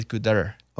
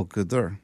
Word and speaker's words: Qatar: I was watching the actual pronunciation of it. It's Qatar. – Oh, Qatar.